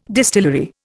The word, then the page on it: distillery: Indian